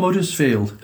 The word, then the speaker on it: Huddersfield: Well, actually, Dave, I'm from Huddersfield. – Oh, yeah? – Don't forget, the land of the silent H. – Huddersfield. – We don't pronounce our H's.